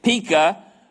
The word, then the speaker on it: Pekah: and Pekah, yeah, that's a long E, isn't it? Pekah, the son of Remaliah, king of Israel.